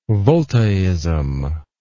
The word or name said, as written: voltaism